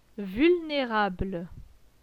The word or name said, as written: Vulnerable